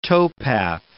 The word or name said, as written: towpath